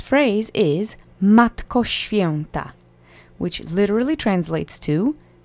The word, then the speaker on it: Matko: The accent is on the first syllable. Repeat after me. Matko. Matko. Matko.